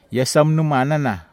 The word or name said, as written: iasamnumanana